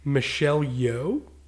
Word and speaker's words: Michelle Yeoh: And it's starring, I think I'm going to get her name wrong, but it's Michelle Yeoh.